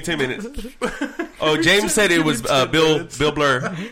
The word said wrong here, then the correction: Burr: Bill Burr.